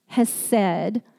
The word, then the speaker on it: chesed: One Messianic rabbi who is featured as a resource in the teaching guide this week said that you can't pronounce it with just an H at the beginning. Chesed. It's a throat-gurgling chesed. And he said, if you're not spitting on the person in front of you, you are not saying it correctly.